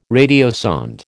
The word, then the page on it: radiosonde: American English